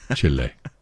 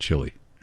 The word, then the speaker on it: Chile: And my friend Harold says I have to pronounce it Chile. Chile, not Chile. Chile. He's from Uruguay.